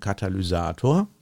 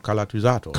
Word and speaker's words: Katalysator: So, und der Techniker weiß, ein Katalysator, ich habe das heute richtig ausgesprochen, ne? – Ja, ja, ja. Hast du das schon mal falsch gemacht? Weiß ich nicht. Na, ich weiß nicht. Manchmal sage ich auch Kalatysator.